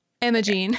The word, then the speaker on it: Imogen: And she collaborated with Imogen Heap, right? Am I saying her name right? – Imogene.